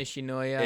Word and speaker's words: Nishinoya: Rolling Thunder Boy Nishinoya.